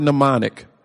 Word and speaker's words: mnemonic: And the word mnemonic, for those of you that may not be familiar with it, is spelled capital M-N as in Nancy, E-M-O-N-I-C. The M is silent when you pronounce it, mnemonic. M-N-E-M-O-N-I-C.